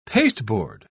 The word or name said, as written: pasteboard